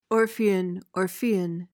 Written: orphean

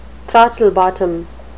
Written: throttlebottom

